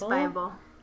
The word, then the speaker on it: Byble: B-Y-B-L-E. No, it's B-Y-B-L-E. That's why I was like, Bible? – It's Bible. – That's weird. Okay. – What else? – Cardly? That's why she uses a pun on the word Bible.